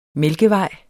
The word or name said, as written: mælkevej